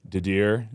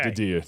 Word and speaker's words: Didier: When you talk about, like, let's go with our fives, you got Didier, if you say it right. You try to take away the R. – Okay. – Just think of it. So okay. Didier, that's okay. It. It's French, I think.